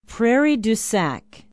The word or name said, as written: Prairie du Sac